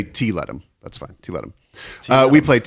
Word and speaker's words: Tiletum: How to pronounce? – Tiletum. Tiletum. But we don't, in English, tum is awkward. So it's Tiletum.